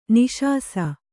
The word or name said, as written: ನಿಶಾಸ